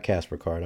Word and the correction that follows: Caper: I'm sorry, I mispronounced that.